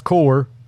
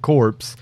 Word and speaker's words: Hardcore: Now, Hardcore Uprising, it is pronounced core. I have gotten jumped on before because I pronounced it corpse.